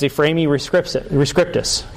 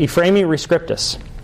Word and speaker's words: Ephraemi rescriptus: This one is Ephraemi rescriptus. Okay? Ephraemi rescriptus.